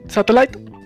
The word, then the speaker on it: Satellite: Satellite.